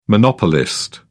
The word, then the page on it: monopolist: British English